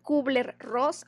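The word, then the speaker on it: Kübler-Ross: curva Elizabeth Kubler-Ross,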